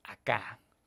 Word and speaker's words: acá: Entonces, se cree muy acá, con acento en la A.